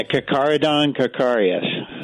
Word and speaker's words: Carcharodon carcharias: 827, Tommy Tucker, WWL, talking about great white sharks, specifically Crystal, a Carcharodon carcharias. How do you say that, Kelly Boyle? – Yeah, Carcharodon carcharias.